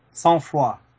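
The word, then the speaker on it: Sang-Froid: Sans froid. Well, in English it would be. It's a name in French.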